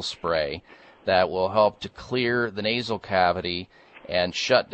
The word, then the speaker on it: Xlear: So we want to take something called Clear Sinus Care. And Clear is spelled with an X. The X stands for xylitol. X-L-E-A-R. Clear Sinus Care.